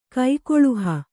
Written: ಕೈಕೊಳುಹ

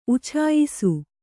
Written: ಉಚ್ಚಾಯಿಸು

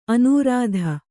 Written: ಅನೂರಾಧ

